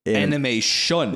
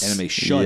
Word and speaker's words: Anime Shun: Let me spell it because it's kind of weird. It's anime and then S-H-U-N. It's not... – Anime Shun. – Yes. – Anime Shun. Yeah.